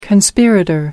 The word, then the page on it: conspirator: American English